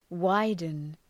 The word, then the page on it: widen: ‘waıdən